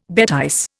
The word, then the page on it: bêtise: Indian